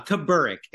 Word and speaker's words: Kaburick: Uh, Kaburick is how you say it.